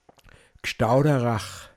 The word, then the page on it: Hoadachbe-isn: Pinzgauer Mundart